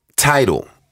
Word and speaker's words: Tidal: Tidal is how you pronounce it.